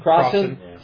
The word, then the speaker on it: Crossan: But as to the existence of Jesus, Crossan, is that how it's pronounced? – Crossan says he's certain.